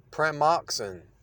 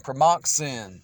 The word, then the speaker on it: Pramoxin: Pramoxin. It's spelled with an X, but it sounds like sin. Pramoxin.